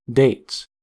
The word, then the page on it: dates: American English